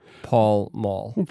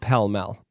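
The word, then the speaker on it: Pall Mall: Guys, if you want to see a really cool ad, search for, on YouTube, Lee Marvin Paul Mall. – We'll put a link on there. – Put a link on there. By the way, it's pronounced Pell Mell.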